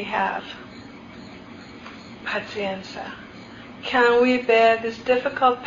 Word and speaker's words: Pazienza: And when difficulties arose, the old people used to say, Pazienza. And they say it like soothing prayer music, not some of our oh, be patient. Pazienza. And with that last ta, you exhale and you slow down and breathe.